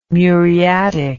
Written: muriatic

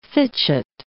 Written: fitchet

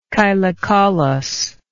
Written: chylocaulous

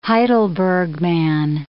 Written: Heidelberg man

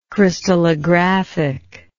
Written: crystallographic

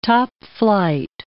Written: topflight

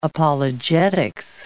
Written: apologetics

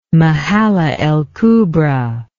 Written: Mahalla el Kubra

